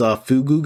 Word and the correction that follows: Flugel: guy. What's was that the right pronunciation? – It was Flugel.